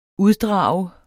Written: UDDRAGER

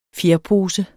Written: fjerpose

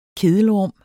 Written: kedelrum

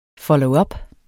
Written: followup

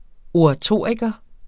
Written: oratoriker

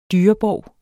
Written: dyrebov

